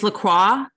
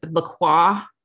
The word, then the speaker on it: LaCroix: Common mistake. It's LaCroix. – Okay. Mm-hmm. Thank you very much. I'm just going to write that down phonetically so I don't mess it up. Croix. – LaCroix is fine though. Just to be clear, LaCroix.